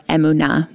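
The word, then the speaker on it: emunah (אמונה): Emunah, or emunah, more properly pronounced in Hebrew.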